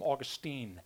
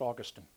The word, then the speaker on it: Augustine: Sometimes they call him Augustine. It's actually pronounced Augustine. St. Augustine was a fourth century, so like the 300s, a fourth century theologian.